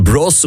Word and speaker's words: Bros: Maar die heeft dus een bros, want zo moet je het eigenlijk echt uitspreken.